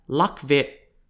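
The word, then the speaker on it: Lạc Việt: The earliest Vietnamese people were known in Vietnamese as Lac Viet, spelt L-A-C-V-I-E-T, or pronounced in Chinese as Luo Yue, spelt L-U-O-Y-U-E.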